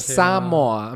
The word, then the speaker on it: Samoa: Samoa. I'm sorry. That's how it's pronounced.